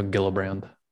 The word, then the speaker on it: Gillibrand: I think it, I think it is a hard G. I think I was wrong. Okay. – So Gillibrand. Gillibrand. Okay.